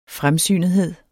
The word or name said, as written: fremsynethed